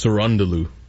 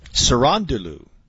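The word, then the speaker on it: Cerúndolo: The way they say it on the broadcast, it's Cerundolo. – Cerundolo.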